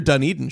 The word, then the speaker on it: Dunedin: I love your Dunedin shirt, by the way. – Thank you. Thank you for pronouncing it correctly, too.